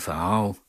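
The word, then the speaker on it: Pharao: Men det er bare simpelthen, fordi jeg hedder Carsten Farao. Godt nok Farao med PH i stedet for F, men det udtales som F.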